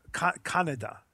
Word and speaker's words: Kaneda: So, I never knew that, actually. It's not Kaneda. It's Kanada? – Kanada. – Kanada. – Kanada. So okay, it's like Canada, but it's Kanada. Yeah. So like with a K.